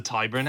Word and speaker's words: Tyburn: Yes, the Tyburn Angling Society.